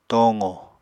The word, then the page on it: tooqo: Apatani